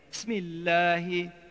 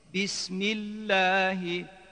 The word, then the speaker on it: Bismillahi: Besmeleye başlarken bazılarının yaptığı gibi aceleyle Bismillahi şeklinde söylemeyin. Bis hecesine iyi basın. – Bismillahi şeklinde telaffuz edin.